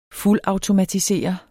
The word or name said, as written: fuldautomatisere